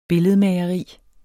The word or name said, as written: billedmageri